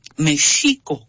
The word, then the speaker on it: México: Voy a empezar desde la pronunciación original de México en náhuatl, era México con uh-huh. acento en la I. – Uh-huh. – Y la pronunciación era como shampoo. Ajá. O shower en inglés.